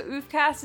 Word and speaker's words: oofcast: I also want to say, so oofcast is OOHFcast. Yes. Don't forget, there is a silent H there.